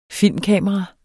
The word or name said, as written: filmkamera